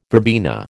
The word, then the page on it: verbena: American English